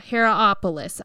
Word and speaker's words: Hierapolis: Hierapolis. Okay. I'm assuming I'm saying that right. – I think so.